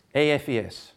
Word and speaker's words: AFES: AFES, is that how you say it? AFES. So like IFES, but you're AFES.